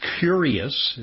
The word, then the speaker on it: Cureus: Another big review by Peter McCullough, controversial guy, and colleagues in a journal called Curious. It's spelled C-U-R-E-U-S. It's a play on words, like cure us as opposed to, but also it is intellectually curious.